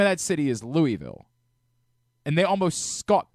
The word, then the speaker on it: Louisville: You can just learn how to pronounce it, Louisville.